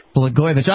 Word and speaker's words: Blagojevich: Blagojevich.